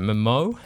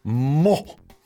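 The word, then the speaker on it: Mmoh: Now, I don't know how you pronounce this name. Mmo? It's M-M-O-H. – Mo.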